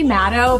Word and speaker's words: Matto: It's Stephanie Matto, but you can call me Fart in a Jar Girl.